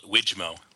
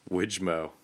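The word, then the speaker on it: Wijmo: I call it Widgmo. – Widgmo, okay. – Widget, more widgets, Widgmo.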